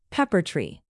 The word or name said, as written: pepper-tree